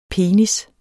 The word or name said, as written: penis